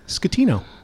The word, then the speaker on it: Scatino: Yes, you did, Scatino. That's right. – Right. Like the way I remember, it's like school. – Yeah, school. School. That's the way I always advise people to pronounce it.